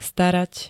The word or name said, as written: starat